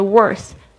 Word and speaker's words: worth: 这个 worth, W-O-R-T-H, worth, 就是呢，值多少。这个本来可以当动词，也可以当名词， worth, W-O-R-T-H, 特别注意一下，这个发音是有点难，就是中文没有的是声音，应该是卷舌的这个 r 的声音 r r r，这个 th 呢是舌尖轻轻的吐出来，在两排牙齿之间，然后发出这个气音 worth。